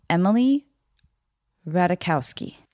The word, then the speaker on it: Ratajkowski: Okay, Emily Ratajkowski. – Yes, that was right. – Oh, thanks. Michael Sherman told me to not pronounce the J.